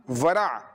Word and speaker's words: ورع: واو کا ورع ہے, واو را عین ورع.